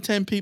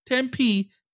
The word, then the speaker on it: Tempe: Then you have to pronounce the name of the city correctly. It's Tempe. Tempe, not Tempe.